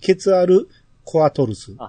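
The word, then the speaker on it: ケツァルコアトルス: でも、このちっちゃい泡は普通に発音していい泡やと思うんですよ。僕がよく言う。 – はいはい。 – 発音していい泡と、発音しない泡とある、あると思うんですけど、これは、ケツアでいいと思うんですよ。ケツアルコアトルス。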